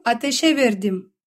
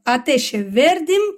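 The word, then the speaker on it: ateşe verdim: Певец у нас произносит «атешевердим яктымда гельдим». Но на самом деле верно будет произнести «атеше вердим яктымда гельдим».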